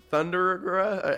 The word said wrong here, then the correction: Thundaga: I can't even pronounce Thundaga.